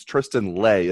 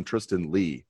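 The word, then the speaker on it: Tristan Leigh: I'm back to mispronouncing names. It's Tristan Lay, isn't it? I've been calling him Tristan Lee. It's Tristan Lay. Is that correct? – Yeah, he is an outstanding prospect.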